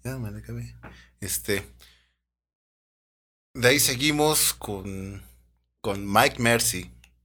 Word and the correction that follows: Mick Marcy: Mick Marcy, güey. – Mick Marcy. Mick Marcy, güey. Bueno, la pronunciación.